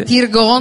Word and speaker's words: Tirgan: Puria Jashan Tirgan. E questa in realtà è la pronuncia italiana, perché quella iraniana è ancora diversa. – Dici quella iraniana? – Quella iraniana è Puryo Jashan Tirgan.